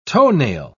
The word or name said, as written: toenail